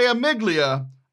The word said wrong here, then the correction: amygdala: I mean amygdala.